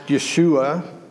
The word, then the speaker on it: Yeshua: And Joshua, then, this man whose name is pronounced Yeshua, which